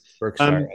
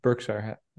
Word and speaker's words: Berkshire: Berkshire, as we would say, just to make sure everyone caught the pronunciation there. Berkshire.